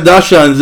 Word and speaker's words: Dachshunds: It looks like 5 Dachshunds like running after you. – Wow. – That's how you pronounce it, right? – Yes. – It's close, it's close.